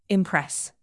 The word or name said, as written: impress